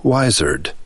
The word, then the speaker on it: wizard: A person who is wise is a wizard. Since the S is pronounced as a Z, it came to be spelled with a Z. Wizard.